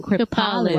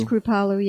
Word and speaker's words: Kripalu: Everybody needs to know how to pronounce that. They always say, how do you say that? – That's right. – Kripalu. – Kripalu. – What is Kripalu yoga? – Yes.